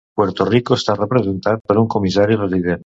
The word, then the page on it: resident: Catalan